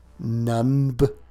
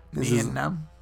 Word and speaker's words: Nunb: Nunb. Well, it's spelled N-U-N-B. Like, who can...